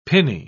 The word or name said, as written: pinny